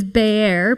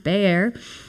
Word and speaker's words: Be'er: The word wells is Be'er, pronounced Be'er. Not beer. It's Be'er.